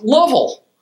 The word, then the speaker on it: Louisville: Louisville is pronounced Lovell by the locals.